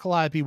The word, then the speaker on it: Calliope: People will mispronounce her name. They call her Calliope, Calipe, things like that. Like they cannot pronounce that name.